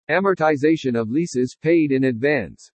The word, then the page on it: AMORTIZATION OF LEASES PAID IN ADVANCE: english